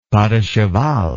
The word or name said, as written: pas de cheval